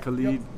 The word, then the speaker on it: Khalid: I think I hope I said his name right. Khalid. I like that song. – Yeah. – Khalid. – Yep.